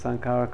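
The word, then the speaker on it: saṅkhāra: Okay, so sankharas, basically formation constructions, and how do you pronounce sankhara correctly? That's the right way, sankhara.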